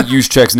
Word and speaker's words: Juszczyk: Listen, if you guys are listening to me, go pull up Kyle Juszczyk's name and you tell me how to say it.